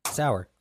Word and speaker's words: sour: It's like hour. – It's sour. – Yeah. – Hour. – It's hour. Not hour, it's hour. – Ow. – Phonetically, the way you would spell it is O-W-E-R.